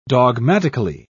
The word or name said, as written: dogmatically